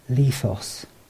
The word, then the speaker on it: lithos: Stone in the Hebrew of the Old Testament is pronounced aben, and in the Greek of the New Testament is pronounced lithos.